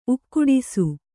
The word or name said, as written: ಉಕ್ಕುಡಿಸು